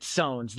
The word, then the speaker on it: SOENs: We pronounce it SOENs. That's just kind of how it evolved over the years.